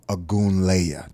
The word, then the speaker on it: Ogunleye: Played in the league for 11 years, undrafted, came through the ranks, powerful guy, strong guy, none other than Adewale Ogunleye.